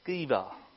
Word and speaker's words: Sceva: And then after mentioning that, in verse 11 onwards, we read of the seven sons of Sceva.